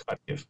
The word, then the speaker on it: Kharkiv: And now there's another city, and it's Kharkiv. Did I say that right? – That's close. Yeah. – Okay. – That's Kharkiv.